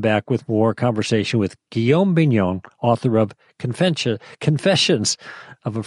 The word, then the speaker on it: Guillaume Bignon: It's like William in French. Did I get that right? Yes, I think I did. Yeah, G-U-I-L-L-A-U-M-E, last name B-I-G-N-O-N. That's like a big non.